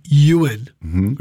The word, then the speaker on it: Ewan: My name is actually Ewan, which is E-W-A-N, which is a Welsh name. But if you pronounce it in Welsh, it's Ewan.